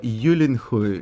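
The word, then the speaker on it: Gyllenhaal: I njegovo prezime se izgovara Julinhul, ili Julinhul, tako nekako.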